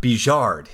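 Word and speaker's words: Bigeard: The other man, whose name is spelled in English, Big-Eared, but who will be appearing more and more, so I should probably start pronouncing his name correctly, which I know now is correctly, because now I have checked, Bijard.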